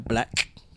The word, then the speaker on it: 6LACK: Okay, so my hookup this week is an album by Black. That's spelled 6LACK. – Is he dyslexic? No, I'm joking. – I actually never knew it was Black. I've seen it before. I keep saying 6LACK.